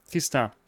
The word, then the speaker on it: Tristan: In French it's pronounced Tristan, so Tristan is still inaccurate, but it's closer. The actual the final N at the end is silent. So you're not even supposed to pronounce the N.